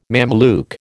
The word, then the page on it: Mameluke: American English